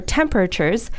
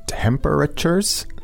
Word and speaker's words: temperature: Temperatures? – Yeah, it's a little bit difficult to pronounce, right? – Mm-hmm, yeah. When I say this quickly, I usually say it like this. Temperature.